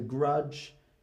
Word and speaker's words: grudge: The letter D is not pronounced in the DG combination. So grudge, hedge, pledge, and dodge.